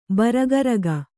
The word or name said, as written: ಭಾರಕಾರಕ